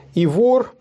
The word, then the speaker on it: Ивор: Ивор, посол Игоря, великого князя русского. – Ивор или да. Ивор? – Ну, Ивор, можно и так, то там ударение там не стоит.